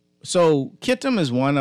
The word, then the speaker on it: Kittim: The texter was saying that Portugal is called Kitum today, or was called Kitum. – I didn't get that word properly. Kittim? – Kittim. Kittim. Kittim is one of the sons. – Spell it. Because you're not coming over clear. – C-H-I-T-T-E-M. C-H-I-T-T-E-M.